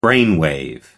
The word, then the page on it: brainwave: American English